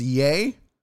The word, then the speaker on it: Ye: It's pronounced Ye.